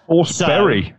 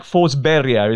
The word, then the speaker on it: Forsberg: How do you say that name? – Forsberry, so, according to UEFA. – Yeah, this is one of those, right? – It's more like Forsberia, isn't it?